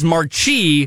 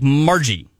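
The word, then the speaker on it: Marchie: It's M-A-R. Okay, Mar. – Yeah. – C-H-I-E. – Which I would pronounce as Marchi. – Marchi. – But the internet's telling us more like Margie. – Marchi? – Well, almost like with a G.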